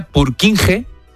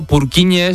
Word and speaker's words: Purkinje: Es que se escribe, en español sería Purkinje. – Purkinje. – Pero Purkinje es en checo, que es por porque es el apellido del descubridor,